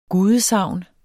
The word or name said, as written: GUDESAGNET